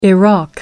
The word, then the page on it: iraq: American English